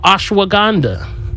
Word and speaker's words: ashwagandha: It's called ashwagandha.